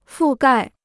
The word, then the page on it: 覆盖: fù gài